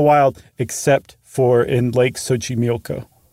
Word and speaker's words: Xochimilco: It's Lake Xochimilco. And friends, look up Xochimilco. It is not in any way, shape, or form, as far as an English speaker is concerned, spelled like it's pronounced.